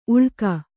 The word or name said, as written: ಉಳ್ಕ